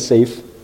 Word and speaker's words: Saif: My name is Saif, S-A-I-F. My last name is Mahmoud, M-A-H-M-O-U-D.